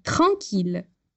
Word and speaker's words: tranquille: No entanto, para as palavras mille, ville e tranquille, a gente vai pronunciar os dois L's com o som de L, normal. Então, lembra lá: ville, mille. Et tranquille.